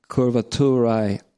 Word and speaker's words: curvaturae: It's spelled C-U-R-V-A-T-U-R-A-E.